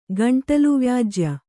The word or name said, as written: ಗಂಟಲುವ್ಯಾಜ್ಯ